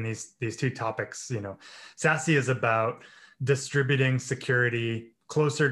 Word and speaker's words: SASE: which is pronounced SASE.